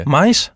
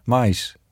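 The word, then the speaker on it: maïs: Mais? – Mais. Mais. Met een tremel op de i.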